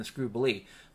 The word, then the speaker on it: Scroobilee: Ordinary people get screwed in the Scroobilee. But in the Scroobilee, rhymes with Jubilee, the most powerful scumbags that ever lived, they get a giant check for nearly fucking the world every 8 years.